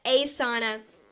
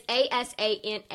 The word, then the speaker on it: Asana: I'm sorry that I mispronunciated. So yes, Asana. It's Asana. It's all one word.